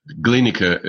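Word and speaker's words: Glienicke: Just Gleinika, it's pronounced, Gleinika.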